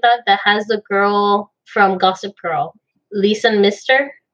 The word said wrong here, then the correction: Leighton Meester: I can't say her name. – Oh, Leighton Meester.